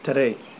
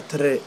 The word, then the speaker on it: Tarey: And then my last name is T-A-R-E-Y. – And how do you say that last name? – Tere. – Tere. – Yeah. – Okay, like with a roll? Tere? – Yeah, Tere.